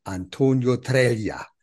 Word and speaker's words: Antonio Treglia: Einen Spezialisten habe ich heute eingeladen, Antonio Treglia.